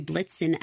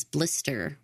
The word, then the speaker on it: Blitzen: When the children are naming off the Santa's reindeer, one of them refers to Blitzen as Blister, which is super cute. And they did not correct it because it is so much more common for young kids to mispronounce the names. I mean, Blitzen is a bizarro name. Blister makes more sense.